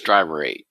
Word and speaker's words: Driver.8: Is that the way you pronounce it, or is it just Driver 8? – Either way, it's kind of like a computer, like, you know, run.exe, so Driver.8.